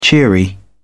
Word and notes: cheery: British English

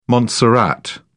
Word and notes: Montserrat: British English

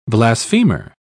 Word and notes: blasphemer: British English

